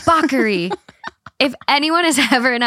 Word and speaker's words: Bacari: It's spelled Bakari. B-A-C-A-R-I. Bakari. But it's pronounced Bakery.